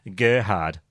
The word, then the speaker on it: Gerhard: Gerhard. Tip, roll the G.